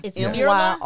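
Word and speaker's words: Myrla: How do you say her name? – Mirla. – It's Mirla. – Y-R-L-A. Yeah. Mirla.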